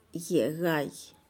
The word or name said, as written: ye'egaih